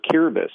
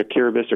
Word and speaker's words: Kiribati: So since really 2005, I've been going to a country called, it's actually pronounced Kiribati, like the T and the I at the end sounds like an S. – Oh. – So Kiribati or Kiribati, if you're Australian.